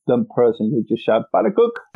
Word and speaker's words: pannenkoek: Maybe slightly related to a Stroopwafel because it's round and it's flat is the Dutch pancake called the pannenkoeken. Yeah. – Pannenkoek. – Pannenkoek, is that how you say it? Pannenkoek? – Pannenkoek. – Okay.